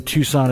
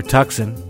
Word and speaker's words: Tucson: Apparently they're from Tucson as well, or Tucson, if you're pronouncing it phonetically.